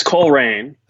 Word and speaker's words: Colerain: It's Coleraine.